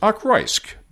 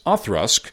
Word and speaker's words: Auchroisk: Now, you'd think a distillery that's spelled A-U-C-H-R-O-I-S-K would be pronounced Achroisk, but it's Othrusk, according to the pronunciation guide in David Wishart's excellent book, Whiskey Classified.